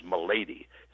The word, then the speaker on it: Milady: My real last name is Milady. It's Irish. most people mispronounce the hell out of it. Melody, malady, melody.